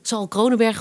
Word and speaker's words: Sal Kronenberg: Nou, dat, gelukkig hebben we daar de, Sal Kronenberg. zeg ik het goed? Ja, Sal Kronenberg gevonden.